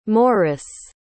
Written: morice

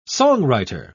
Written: songwriter

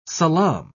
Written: salaam

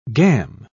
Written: gam

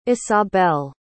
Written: isabell

